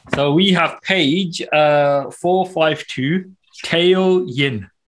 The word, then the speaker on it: Tao Yin: So it's spelled T-A-O. And yep. Yin is as in Y-I-N, right?